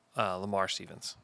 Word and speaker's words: Lamar Stevens: Lavar Stevens. I think that's who it was. Lamar Stevens. Oh my gosh. I've said his name wrong 500 times.